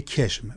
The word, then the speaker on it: قشم: مثلا یه جنگنامه‌ای هست که معروف به جنگنامه کشم، میدونید جنوبی‌ها قه رو که تلفظ می‌کنن. – بله، مثلا قشم رو میگن قشم، پرتقال رو میگن پرتقال.